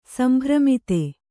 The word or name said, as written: ಸಂಭ್ರಮಿತೆ